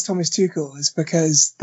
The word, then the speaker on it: Tuchel: Thomas Tuchel, I've been taught that's the right way to say it.